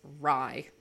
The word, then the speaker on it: Rhy: Okay, this one, I'm gonna put it out there that I read his name as Rye. – That's correct. – Ayo, nice. Okay, it's spelled R-H-Y for those of us who are wondering.